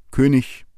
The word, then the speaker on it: König: Die EG-Regel besagt, dass ein EG am Ende eines Wortes oder am Ende einer Silbe als ICH gesprochen wird. Das heißt, es heißt König, innig, heilig.